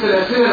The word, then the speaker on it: سلاسل: يبقى لو هقف عليها اقولها ازاي يا اخوانا؟ سلاسل، احسنت، سلاسل الوقف الصحيح. حفص وقف هكذا ووقف كذلك بالالف. يبقى حفص له في هذه الكلمه بالذات يا احباب وقفان